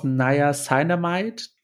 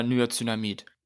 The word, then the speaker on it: Niacinamid: Äh, wie spricht man das aus? Naya Cyanamide. Ach so, ich sage immer Niacinamid